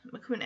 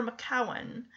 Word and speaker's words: Macoun: M-A-C-O-U-N, Macoun apple. Cross between Macintosh and Jersey Black cultivars. Let's see. Pronounced either Macoun or Macowan, developed at the New York State Agricultural Experiment Station in Geneva by Richard Wellington, first introduced in 1923 as an eating apple.